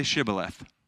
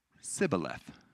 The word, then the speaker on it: shibboleth: If the man said, no, I am not, they would say to him, say shibboleth. If he was from Ephraim, he would say sibboleth. Because people from Ephraim cannot pronounce the word correctly.